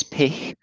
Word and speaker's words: Pich: Okay, so my surname is Pich, and it's P-I-C-H. But feel free to call me Pich.